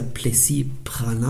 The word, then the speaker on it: Praslin: Und das ist ja Plessis-Pralin und das schreibt man P-R-A-S-L-I-N.